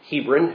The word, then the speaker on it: Hebron: Adullam or Adullam or Adullam, people pronounce it different ways, was a town only about a mile northwest of Hebron, or Hebron, if we're going to pronounce it the local way here, as the name of this church is pronounced.